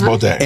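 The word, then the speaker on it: Bode: It's the designer's B-O-D-E. – Baudet. – I think it's Baudet. – It's Baudet.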